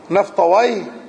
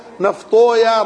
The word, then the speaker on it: نفطويه: هو إسحاق بن راهويه وكلمة راهويه وسيبويه ونفطويه تلفظ لفظين لفظ أهل اللغة هكذا سيبويه نفطويه مهرويه ولفظ أهل الحديث يقولون سيبويا نفطويا راهويا وكلاهما جائز والألفاظ الأعجمية يتوسع فيها نعم